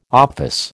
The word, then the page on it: office: American English